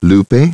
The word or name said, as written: Lupe